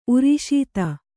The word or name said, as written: ಉರಿಶೀತ